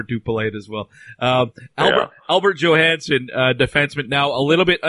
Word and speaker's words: Johansson: Albert Johansson, is it Johansson or Johansson? – Johansson. – All right. I never know if I'm pronouncing the J as a J, as we do here, or like a Y.